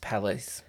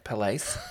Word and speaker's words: Palais: It's pronounced Palais. Palais.